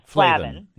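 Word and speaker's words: flavan: So it's F-L-A-V-A-N, Flavin. Yeah, Flavin, yes. Okay, yeah. – Yes, -3-OL, which is O-L-S.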